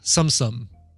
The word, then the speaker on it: Tsum Tsum: So I have recently gotten hooked on a game style called Tsumsum, but it's spelled T-S-U-M, T-S-U-M.